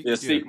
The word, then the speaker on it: Sikh: Sikh, my bad.